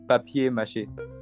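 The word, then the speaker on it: papier-mâché: Papier mache. – Yeah. – Yep. – I mean, isn't it paper? – Papier is the word for paper.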